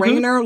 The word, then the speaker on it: Rainer: Rainer like rain with an ER on the end.